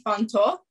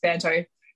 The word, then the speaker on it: Fanto: Kat, please tell us, am I pronouncing your name correctly? Is it Fanto or Fanto? – It would ideally be Fanto, but while I've been here in Australia my whole life, it's just Fanto.